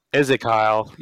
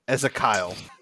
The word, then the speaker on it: Ezekyle: Yep, it is Ezekyle. Ezekiel, yeah. – Ezekiel.